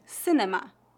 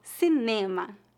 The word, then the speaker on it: cinema: Como se pronuncia a palavra cinema? – em português. – Cinema, cinema, cinema. Aqui, a principal diferença seria a sílaba tônica, que é a penúltima, cinema.